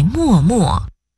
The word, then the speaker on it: 脉脉: 但是你说读作默，什么时候读默呢？ – 默只用在默默，默默是形容词，形容含情凝视或用眼神表达情意的样子。